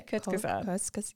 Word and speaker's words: Kurzgesagt: So it's a new YouTube video titled 4.5 billion years in 1 hour by, I hope I'm saying this right, Kurzgesagt? – Yeah, Kurzgesagt. – Yes.